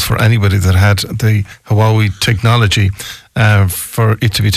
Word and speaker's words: Huawei: Because I remember around the time that, you remember Huawei, is that how we pronounce it? – Yes, Huawei. – Huawei, yeah.